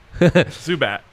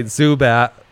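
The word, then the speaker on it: Zubac: But what'd we decide on the last name pronunciation? Zubak? – Zubat. – Zubat. – Like the Pokemon. – Oh, right. Zubat.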